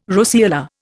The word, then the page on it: roseola: Indian